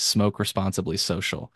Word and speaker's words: Smoke Responsibly Social: Smoke responsibly social.